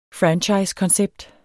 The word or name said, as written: franchisekoncept